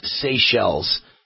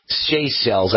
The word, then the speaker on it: Seychelles: The island country is pronounced Seychelles. Seychelles. I got that from both Larry and Colonel Joe, two of my great listeners. Thank you both for educating me. Seychelles is how you pronounce that country.